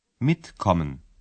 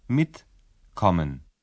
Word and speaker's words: mitkommen: Antes de seguir adelante, presten atención al infinitivo y a cómo se acentúa la primera sílaba. – Mitkommen. Mitkommen.